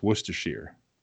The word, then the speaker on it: Worcestershire: In New England, we just changed the shire to sheer is all. So it's Worcestershire. That's all it is.